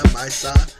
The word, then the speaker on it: Maysa: And I'm not pronouncing her name right. Maya, Maisa. M-A-Y-S-A.